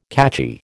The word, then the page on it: catchy: American English